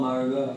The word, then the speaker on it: Margot: Yeah, like Manuel Margot. – Yeah, yeah. I mean, now like, I realize that you're a cool one if you have a silent T.